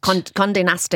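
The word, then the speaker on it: Condé Nast: So I'm not sure I'm pronouncing this properly, but Conte Nast. – Conte Nast or something, isn't it? – Yes, yeah.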